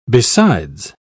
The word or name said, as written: besides